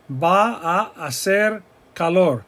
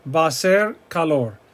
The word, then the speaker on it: va a hacer calor: Va a hacer calor. But it's not pronounced like that. It's va a ser calor, va a ser calor.